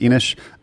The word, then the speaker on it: Inis: Just another expansion, a game called Enish or Inis. I believe it's supposed to be pronounced Enish, which is a dudes on a map board with some very cool Euro elements to it.